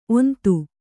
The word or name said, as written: ಓಂತಿ